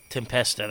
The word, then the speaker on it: Tempesta: John Tempesta, is that how you say his name? – Tempesta, that's how I always said it.